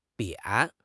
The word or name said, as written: 扁